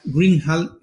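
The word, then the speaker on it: Greenhalgh: Trisha Greenhall es como se pronuncia y lo pondremos ahí para se llama How to Read a Paper.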